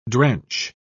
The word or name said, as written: drench